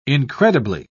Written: incredibly

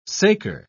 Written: saker

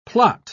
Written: plot

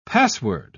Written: password

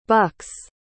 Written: bucks

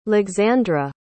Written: lexandra

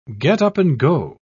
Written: get-up-and-go

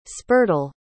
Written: spurtle

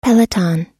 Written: peloton

